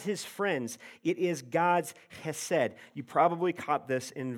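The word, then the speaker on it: chesed (חסד): You can even say it like you have something in your throat, chesed, if you want, okay?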